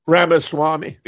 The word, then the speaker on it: Ramaswamy: Ramaswamy.